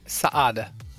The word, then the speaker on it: Saade: Saade.